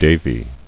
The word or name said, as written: Devi